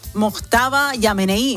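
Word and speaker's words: Mojtaba Khamenei: El nuevo líder supremo, Mostaba Yamenei, dice... ¿Eh? Mojtaba Yamenei. No. Me dijiste que era así, ¿no? – Sí, pero lo he dicho tan mal.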